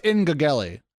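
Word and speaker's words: Engageli: That is Engage L-I, which has to be the most impossible startup to find given it's pronounced Ingageli, actually.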